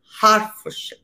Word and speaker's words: Hertfordshire: Y luego, y, en Herefordshire, y yo vivo en Herefordshire. Bueno, se dice Herefordshire, se dice Hertfordshire.